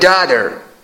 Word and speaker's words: daughter: وبسایت مچه زبان ایران که امریکایی داتر نمیگن، تی رو تلفظ زیاد علاقه به تلفظ تی ندارن امریکایی ها، میگن my daughter.